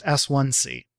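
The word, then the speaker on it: S-IC: Um, the S1C, and you'll see this written as SIC in all caps, but it's pronounced S1C.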